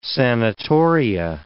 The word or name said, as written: sanitoria